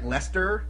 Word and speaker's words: Leicester: Just, freaking like Leicester, you know, like Leicester Square.